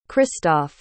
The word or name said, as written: christoph